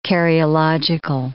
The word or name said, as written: karyological